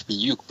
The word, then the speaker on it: Ryuk: You're saying Ryuk, like Ryu the Street Fighter? It's Ryuk, bruh.